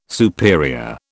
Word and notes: superior: British English

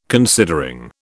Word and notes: considering: British English

